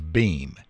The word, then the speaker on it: Beam: I mean, my last name is Beam, B-E-A-M.